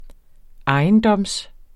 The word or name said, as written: ejendom